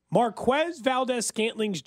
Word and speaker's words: Marquez Valdes-Scantling: I went back to that Cincinnati game, and then you realize how easy Marquez Valdes-Scantling's job is.